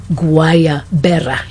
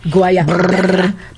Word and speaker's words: Guayabera: So the shirts, she's familiar with the shirts, so here's how you pronounce it. Guayabera. Did you notice the twirling in my pronunciation? – Yeah. Mm. You know I'm envious because I can't roll my R's. – Yeah. Guayabera.